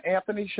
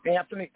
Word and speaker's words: Anthony: They can get me at Brother Anthony Shaheed. Anthony, A-N-T-H-O-N-Y. Anthony Shaheed. My last name is spelled S-H-A.